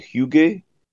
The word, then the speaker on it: hygge: So I've been, philosophically looking at the Danish idea of, I think they say it, hygge. It's spelled Hygge, and it's living the experience or being in the moment.